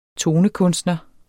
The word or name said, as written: tonekunstner